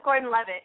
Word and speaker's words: Gordon-Levitt: Joseph Gordon-Levitt, yeah.